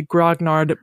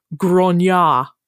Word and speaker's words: grognard: And I will tell you that the original pronunciation of this word is not actually grognard, but grognard.